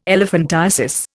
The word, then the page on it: elephantiasis: Indian